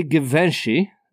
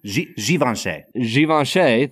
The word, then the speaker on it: Givenchy: Fact number three, although Hubert de Givenchy, and I know I'm saying that wrong. – Givenchy, thank you.